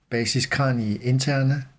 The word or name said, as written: basis cranii interna